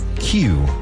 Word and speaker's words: queue: Q. – Cola o fila. Se escribe Q-U-E-U-E, pero solo pronunciamos la letra Q.